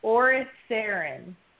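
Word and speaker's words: Oriceran: It's pronounced Orisarin.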